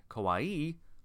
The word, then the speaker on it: kawaii: Kawaii and kawaii. Just make an O for that. Kawaii means scary. Kawaii, nay, means scary. Kawaii, long E at the end, means cute.